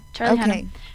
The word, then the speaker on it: Charlie Hunnam: Charlie Hunnam.